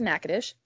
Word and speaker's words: Natchitoches: It's spelled like if you were to read it, like in how it sounds or spelled, it's Natchitoches, but it's pronounced Natchitoches.